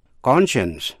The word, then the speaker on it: conscience: Âm C phát âm là SH. Conscience là lương tâm.